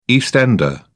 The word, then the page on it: east ender: British English